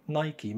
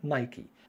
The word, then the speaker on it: Nike: النطق الصحيح ليها هو نايكي، لأن الشركة اتسمت على اسم الأسطورة اليونانية القديمة لما يطلقوا عليه عندهم آلهة النصر. إلهة النصر عندهم اسمها باليونانية نايكي،